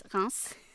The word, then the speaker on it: Reims: it's Reims.